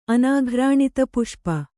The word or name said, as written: ಅನಾಘ್ರಾಣಿತಪುಷ್ಪ